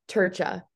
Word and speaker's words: Trca: Okay, so it's like Tur and then Cha, so like Turcha. It's Turcha. So weird, no one ever gets it right.